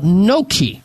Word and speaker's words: Gnocchi: It's called Gnocchi. You've heard it's spelled G-N-O-C-C-H-I, Gnocchi.